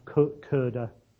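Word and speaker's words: Köder: There should be what's called an umlaut, two little dots above the O of Koerder, so it's pronounced that way.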